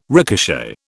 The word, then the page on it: ricochet: British English